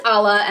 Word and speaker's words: Alla: A-L-L-A. – Oh. – So, Allah or Alla? – Alla Nazimova. Mm-hmm. I don't know her. – But I think it's Allah, and that's how I'm going to say it.